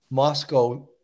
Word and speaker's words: Moscow: Well, you know, Melanie, the line that he said, Moscow rhymes with Costco,